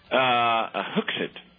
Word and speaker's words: Hooksett: Hooksett. – How do you spell that? – H-O-O-K-S-E-T-T.